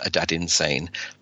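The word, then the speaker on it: Adaddinsane: It's A-D-A-D-D-I-N-S-A-N-E. And for any Bowie fans out there, you'll realize that it's actually Aladdin Sane, but with the L changed to a D.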